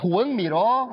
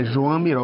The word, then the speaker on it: Joan Miró: Muito obrigado por nos apresentar um universo que, a princípio, pouca gente conhece quando pensa nesse grande artista que é Juan Miró? Você falou uma outra pronúncia? – Na verdade, é Joan Miró.